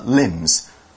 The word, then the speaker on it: limb: Another point to mention about limb is it's got the letter B, but you don't really pronounce the letter B. You kind of pronounce it just like L-I-M, limbs. It's like lamb, I guess. It's one of those words without the B.